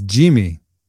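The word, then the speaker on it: Yimi: There's also Y-A-I-M-I Garcia. How do you pronounce his name? – It's Jimmy. – It's Jimmy. – Yeah. Yeah, the Blue Jays crew. They corrected me.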